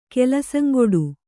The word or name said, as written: ಕೆಲಸಂಗೊಡು